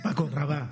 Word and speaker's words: Paco Rabanne: Paco Rabá. – Paco Rabá, ¿no? Con acento en la. – Todo seguido. Con la L sin pronunciar. L líquida.